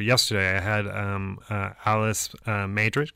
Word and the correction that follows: Medrick: Alice Medrick. – Medrick, thank you. Yes. I always say it wrong a little bit.